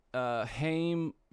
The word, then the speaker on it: Haim: So, this guy, Haim... Is that how you pronounce that? H-A-I-M? That'd be Haim? – That's how I would say it. It's probably, like, Haim.